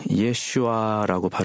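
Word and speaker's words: 예슈아 (ישוע): 그 지역 사람들은 예수님을 예슈아라고 발음한다고 합니다. 사실 이것이 성경이 본래 쓰여졌을 때 불려진 예수님의 이름입니다.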